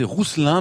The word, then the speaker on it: Rouxelin: Et puis, on a un double diminutif à partir de Roussel, qui est Rousselin, R-O-U-X-E-L-I. Attendez, je recommence. R-O-U-X-E-L-I-N, porté dans le département de la Manche. – Vous prononcez Rousselin, même si c'est un X. – Voilà, exactement.